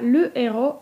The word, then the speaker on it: le héros: Wyjątkiem od zasady, że h pozostaje przedechowe w obrębie wyrazów należących do jednej rodziny, jest para le héros i l'héroïne, gdzie w rodzaju męskim le héros mamy h aspiré, a w rodzaju żeńskim l'héroïne mamy h muet.